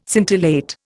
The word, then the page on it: scintillate: Indian